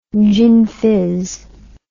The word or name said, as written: gin fizz